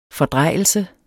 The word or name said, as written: fordrejelse